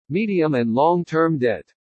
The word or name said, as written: MEDIUM AND LONG-TERM DEBT